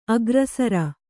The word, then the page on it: ಅಗ್ರಸರ: agrasara